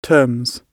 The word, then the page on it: terms: British English